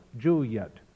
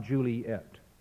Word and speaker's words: Juliet: The only way to get 10 syllables out of these lines, out of these 9 words, is to pronounce the name in 2 syllables, Juliet, not Juliet.